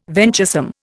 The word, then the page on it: venturesome: Indian